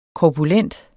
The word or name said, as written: korpulent